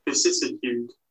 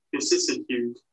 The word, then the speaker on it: vicissitude: Vicissitude. So, I, as I've pronounced that totally wrong, how do you pronounce it? Vicissitude.